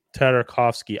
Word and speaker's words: Tartakovsky: Genndy Tartakovsky? – Tartakovsky. I always forget how to say his last name. Tartakovsky.